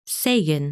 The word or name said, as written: Segin